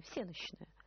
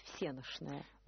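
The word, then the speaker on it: всенощная: А пишется оно с буквы «Щ», я это знаю, да? – Да. – Я бы его произнесла по буквенно «всеночная». Хотя, наверное, надо произносить «всеношная».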